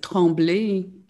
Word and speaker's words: Tremblay: And as an Aussie, I actually said Tremblay, but it is Tremblay, isn't it? Because you're French and you're Gatineau, Canada. So can we say that correctly with the beautiful accent that you have? – So Louise Tremblay, and it is in Gatineau.